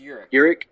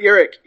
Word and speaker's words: Urich: And Robert Ulrich's character, did I say that right? – I think it's Urich. – Urich. Oh, boy. Well, I did voice text or voice typing, and I really screwed that up. Sorry, bud. Robert Urich.